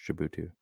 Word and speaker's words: Djibouti: How do you say that? I believe it's pronounced Djibouti.